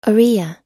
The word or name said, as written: oriya